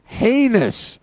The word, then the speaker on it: heinous: heinous betrayal of John Cena. – Heinous. Rhymes with anus.